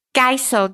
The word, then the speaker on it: Geisel: My name is spelled, it's a long one, but it's the easiest way to find me, ELLYNANNE, no space, Geisel, GEISEL.